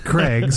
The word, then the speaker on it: Craig: Craig Miller. – Man. – There are not that many letters in my name. – And how do you spell that, Greg? – It's Craig, C-R-A-I-G.